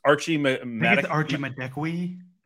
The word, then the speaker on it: Archie Madekwe: Archie Madekwe. – You're my pronunciation guy now. Archie Madekwe,